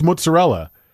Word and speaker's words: mozzarella: That's how you say the word. I did this on the show the other day, and I got ripped apart, and I'm like, that's how it's pronounced, mozzarella.